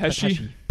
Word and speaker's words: Pesci: Pesci. – Pesci.